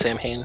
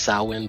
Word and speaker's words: Samhain: Which upsets me because it's actually not pronounced Samhain. It's pronounced Samhain, but be that as it may, as your local Halloween expert, throw that out there for knowledge check for you guys.